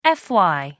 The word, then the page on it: fy: American English